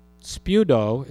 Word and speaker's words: speudo: And spudo is the Greek word, the verb. S-P, how do you spell it? E-U-D-O? Yeah. S-P-E-U-D-O. Sort of like the swimming suit. That's speedos.